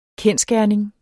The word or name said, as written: kendsgerning